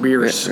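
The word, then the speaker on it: Beres: Beers.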